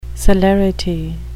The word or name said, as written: celerity